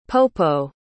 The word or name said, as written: popo